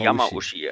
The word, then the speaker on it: Yamauchi: Ähm, dann gibt es ja bei Gran Turismo für uns alle der Gottfaser von Gran Turismo, Kazuhiro Yamauchi. Ich hoffe, ich habe es richtig ausgesprochen. – Ushi? – Yamauchi. – Yamamushi. Yamamushi. Yamauchi, ey.